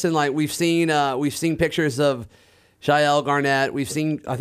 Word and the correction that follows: Chael Garnett: Whoa, whoa, whoa, Chael. You're right. Thank you for calling me out. I need to say his name correctly. Chael Garnett.